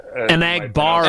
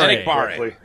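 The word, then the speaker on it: Enagbare: Egbenari, is that how you? – An Agbare. – An Agbare.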